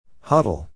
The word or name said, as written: huddle